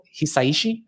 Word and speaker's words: Hisaishi: one of my favorite composers is Joe Hisaishi, I think is how you pronounce his last name.